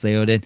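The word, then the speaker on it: Théoden: Theoden, I think I'm saying it wrong the first time, but it's Theoden.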